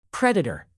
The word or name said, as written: predator